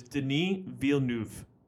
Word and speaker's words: Denis Villeneuve: How you pronounce his name is Denis Villeneuve. – Who? – Denis Villeneuve. – Oh. I'm not calling him that. – Denis Villeneuve. Villanova. – Yeah, Villanova. – It's actually Denis Villeneuve.